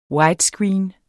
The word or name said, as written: WIDESCREEN